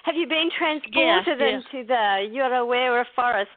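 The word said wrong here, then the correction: Te Urewera: Te Urawera.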